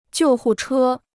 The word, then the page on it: 救护车: jiù hù chē